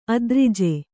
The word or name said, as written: ಅದ್ರಿಜೆ